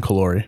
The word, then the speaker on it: Callori: Jason, do you say your last name or? – Yeah, it's, uh, it's Jason Callori.